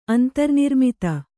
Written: ಅಂತರ್ನಿರ್ಮಿತ

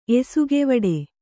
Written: ಎಸುಗೆವಡೆ

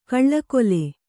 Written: ಕೌಳುಗೋಲ್

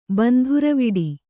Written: ಬಂಧುರವಿಡಿ

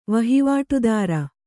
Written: ವಹಿವಾಟುದಾರ

